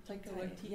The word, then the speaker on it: Taika Waititi: How do you say his name? The, the director of Thor. Taika Waititi.